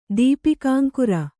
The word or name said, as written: ದೀಪಿಕಾಂಕುರ